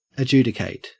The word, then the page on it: adjudicate: Australian English